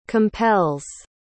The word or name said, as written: compels